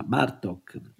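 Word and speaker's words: Bartók: Dunque anche Bartok ha l'accento tonico sulla prima, Bartok.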